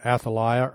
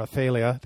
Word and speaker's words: Athaliah: I might as well throw in Athaliah, or Athaliah, they can pronounce it two ways, who's Jezebel's daughter, who had all of her grandchildren executed.